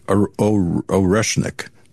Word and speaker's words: Oreshnik: Oreshnik.